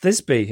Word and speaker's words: Thisbe: Pronounced Thisbe, in case you, like me, are not best read on Greek mythology.